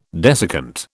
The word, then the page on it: desiccant: British English